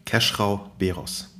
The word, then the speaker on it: Khesrau Behroz: Keschrau Beros.